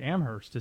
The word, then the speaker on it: Amherst: Now, I mean, apparently Amherst is how you say it.